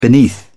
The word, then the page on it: beneath: British English